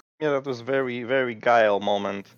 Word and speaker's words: Guile: In that scene where they're in the hospital, I noticed that, so Guiles is how you pronounce his name? I'm so sorry. – Guile, yeah.